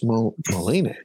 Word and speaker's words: Moline: I think it's Moline. That's the French pronunciation.